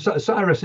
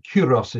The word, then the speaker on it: Cyrus: Cyrus or Chorus. – Both. Cyrus is the anglicization of it. Kuros is how the Greeks would have called him.